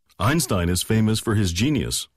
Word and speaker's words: Einstein: 네, 영어식 발음은 아인슈타인이에요. 아인슈타인 아니고요. – Right, very thin S, Stein, 네. Einstein.